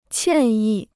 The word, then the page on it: 歉意: qiàn yì